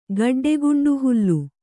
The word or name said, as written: ಗಡ್ಡೆಗುಂಡುಹುಲ್ಲು